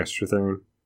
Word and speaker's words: Strathairn: And David Strathairn, is that how you pronounce his name? – Yeah, Strathairn.